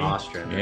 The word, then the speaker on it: Ostrander: Is it Ostrander or Ostrander? – I think it's Ostrander, but I'm going to ask. – Pronounce your last name. – Ostrander. – Yeah, I would have said it wrong. I was saying Ostrander. – Yes, yeah, Ostrander. – Long O.